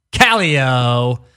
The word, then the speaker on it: Kallio: Second time guest, Michael Kallio.